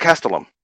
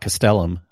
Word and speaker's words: Castellum: Did you notice how Osiris pronounces it? – Yeah, it was Castellum. – Yeah, Castellum. Yeah. – Yeah. – Not Castellum, like we would always say.